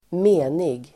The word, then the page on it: menig: [²m'e:nig]